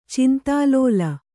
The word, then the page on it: ಚಿಂತಾಲೋಲ: cintālōla